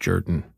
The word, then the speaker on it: Jordan: It's written Jordan, but it's pronounced Jordan, who is almost like a bit of a villain in that film opposite Mark Ruffalo.